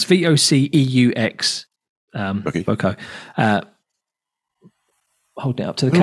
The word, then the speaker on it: Voceaux: Well, called, I don't even know how to pronounce it, Voco. Have you heard of these people? – I know. – And it's spelt V-O-C-E-A-U-X, as in a bit Frenchy sounding.